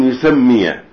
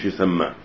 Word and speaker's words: يسمي: أن يكون الاحتمال الأول أن يكون يسمي بصيغة المبني للمعلوم يسمي مش يسمى وحينئذ تكون صريحة في عدم وجوب الإتباع باللفظ الطلاق من غير أن يسمي من غير أن يقول أنت طالق واضح.